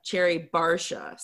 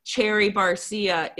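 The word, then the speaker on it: Cherry Garcia: and he pronounced Cherry Barcia, Cherry Barsha. So I have to just say, Gabe, what are you doing? It's clearly Cherry Barcia is the flavor.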